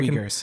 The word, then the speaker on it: Uyghurs: the Uyghurs. Am I saying that right? I Uyghurs. Can, yeah. Uyghurs. Thank you. Yeah. I always get that one wrong.